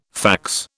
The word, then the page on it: fax: British English